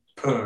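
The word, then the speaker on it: Pugh: Pooh, right? – Yeah.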